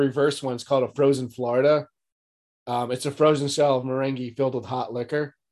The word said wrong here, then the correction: meringue: I think it's pronounced meringue. – Oh, is it? Whatever it is. – I think meringue is like a dance or something.